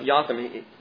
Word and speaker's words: Jotham: Now, Jotham is how you pronounce his name.